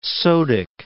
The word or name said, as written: sodic